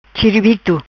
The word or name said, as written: Chiribito